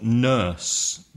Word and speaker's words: nurse: And also the pronunciation of nurse. The vowel sound is like turn or burn, nurse.